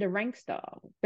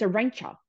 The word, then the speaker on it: Arantxa: that everyone pronounced it a rank star, but it's a rancher.